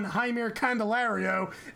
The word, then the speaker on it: Jaime Candelario: We spent a good, like, 5 minutes on, and I'm going to say his name completely wrong for the third time. We spent like 10 minutes on Jaime Candelario, and no, you got it right again.